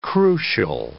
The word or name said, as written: crucial